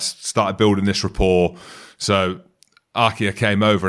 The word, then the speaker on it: RG: And it isn't RG Snyman. It's Arkir Snyman.